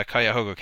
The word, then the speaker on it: Cuyahoga: I think it's pronounced uh, Cuyahoga County.